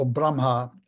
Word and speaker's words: Brahma: Brahma, or Brahma, as it's pronounced in India.